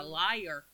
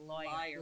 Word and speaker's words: lawyer: He's a liar. – He is. – That's how Brenda pronounces it. He's a lawyer. Liar. – Lawyer.